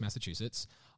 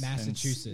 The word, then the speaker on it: Massachusetts: So they're based in Lenox, Massachusetts. And they pronounce that again? – Yeah. – I want to learn. – Why do they make difficult states? – Massachusetts.